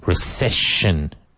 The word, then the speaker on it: recession: Recession. So the second, the middle sound is sh.